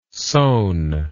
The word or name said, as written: sone